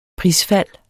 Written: PRISFALDET

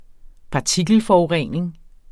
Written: partikelforurening

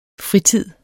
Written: fritid

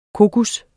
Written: kokos

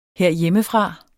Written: herhjemmefra

